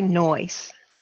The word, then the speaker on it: Noyes: It's actually Noyes. – Noyes. Okay. And it's with a soft S. So Noyes.